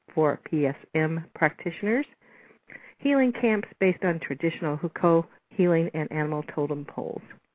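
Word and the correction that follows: Huichol: Wicho is how it's said.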